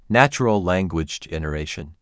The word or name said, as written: Natural language generation